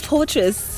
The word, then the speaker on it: Fortrace: The F-O-R-T-R-A-C-E. – Ah, yes, that one. – I have to spell it because people mistake me for Fortress. Fortress is an S.